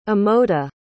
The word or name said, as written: amoda